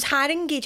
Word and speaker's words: Haringey: It was Haringey, just like what you say. – I said Lee. – Oh, did you? – Is it Haringey? – I think it's Haringey.